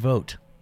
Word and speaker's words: Vogt: You can find me at Brendan Vogt.